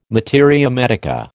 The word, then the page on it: materia medica: American English